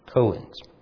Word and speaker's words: koan: It's spelled K-O-A-N, koans.